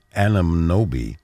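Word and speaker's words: Anunoby: Ananobi. – Yes, OG Ananobi. – Oh, OG Ananobi. Okay. sounds like Obi-Wan Kenobi.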